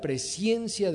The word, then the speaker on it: presciencia: Por eso es que la Biblia nos describe como aquellos que hemos sido elegidos, dice Pedro, según la presciencia de Dios. Y sí pronuncié bien, no es presencia, es presciencia de Dios Padre, en santificación del Espíritu para obedecer y ser rociados con la sangre de Cristo.